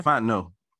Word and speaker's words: Fontenot: Fontenot.